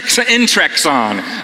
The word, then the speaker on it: Intrexon: So, and by the way, his Twitter handle, his company is at Inextron, just like it sounds. Intrexon. I'm saying it wrong. – Thanks. – At Intrexon. And if you're into biology, you'll notice it's a combination of intron and exon, which is something we may understand.